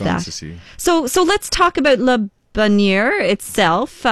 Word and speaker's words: Le Bonheur: Yeah, I think the South African culture, you know, speaking Afrikaans, we kind of pronounce it the way we read it. – Okay. – And that would be Le Bonheur.